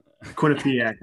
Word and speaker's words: Quinnipiac: Is it Winnipeg or is it Quinnipiac? How do you say Quinnipiac? Quinnipiac.